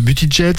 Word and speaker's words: Buttigieg: Buttigieg, c'est ça? – Voilà, c'est fait.